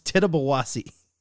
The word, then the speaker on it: Tittabawassee: It's pronounced Tittabawassee Coffee Brown Ale, a 5.5% coffee brown ale.